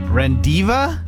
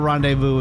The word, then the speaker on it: rendezvous: R-E-N-D-E-Z-V-O-U-S. R-E-N-D-E-Z-V-O-U-S. – Rendiva? Divu? Rendezvous.